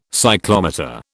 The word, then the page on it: cyclometer: British English